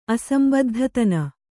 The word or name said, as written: ಅಸಂಬದ್ಧತನ